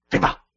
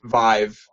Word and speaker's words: Vive: Viva. Viva. Well, Viva. Unfortunately, we were wrong. I was wrong. It's oh. not. It's not pronounced Viva. It's pronounced Vive, like rhymes with hive.